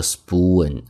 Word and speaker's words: spoon: 注意这个单词读作 spoon, S 后面的 p 读 b, 这是一个清辅音浊化， soup spoon, soup spoon。